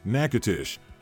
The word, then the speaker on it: Natchitoches: In Louisiana's oldest city, Natchitoches, pronounced Natchitoches, you can celebrate, discover, and explore the destination of travelers since 1714.